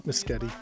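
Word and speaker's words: Mescudi: I assume his last name is pronounced Mascuti, right? – I would think that since his name is not Kid Cudi.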